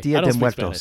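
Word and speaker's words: Día de Muertos: I was in Mexico once for Dia de Muertos. – Did I say it right or wrong? – There's no los. – There's no los? – Yeah. – Okay. – Dia de Muertos. Spanish.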